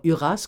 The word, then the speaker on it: Uras: Alors, Huras, comme ça se prononce, oui. – U-R-A-S. – U-R-A-S.